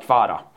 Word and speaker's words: Kvara: De har ju kall, de har, han har sagt att han vill bli kallad Kvara.